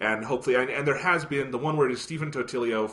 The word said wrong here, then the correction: Totilo: Totilo. – From when you say Totilio, it sounds like a pizza roll brand or something like Tostino's.